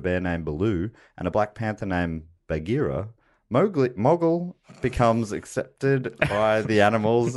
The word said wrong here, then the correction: Mowgli: Actually, I've just double checked. It is Mowgli. I'm sorry. I was mispronouncing it that whole film.